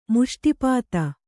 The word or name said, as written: ಮುಷ್ಟಿಪಾತ